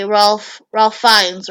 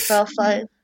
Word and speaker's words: Ralph Fiennes: It was directed by Ralph Fiennes, right? That's how you say his name. – Ralph Fiennes.